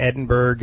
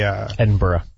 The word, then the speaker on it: Edinburgh: And then you can go up a little north of there into Edinburgh and you can play, Edinburgh.